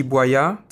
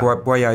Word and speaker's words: Boillat: So it's T-B-O-I-L-L-A-T. It's T Boillat. This is basically T, the letter of my first name, and yeah. Boillat is my family name.